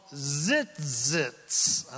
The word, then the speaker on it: tzitzit: These are called tzitzits, and I can't pronounce it any better than that. T-Z-I-T-Z-I-T.